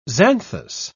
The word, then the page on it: xanthous: British English